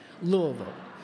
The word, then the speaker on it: Louisville: Louisville.